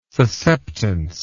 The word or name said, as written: susceptance